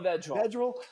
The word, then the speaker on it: Vedral: It's like every year now we have Noah Vedral, is how you pronounce it? – Noah Vedral.